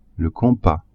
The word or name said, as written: compas